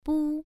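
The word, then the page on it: bu: tone 1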